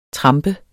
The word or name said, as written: TRAMPES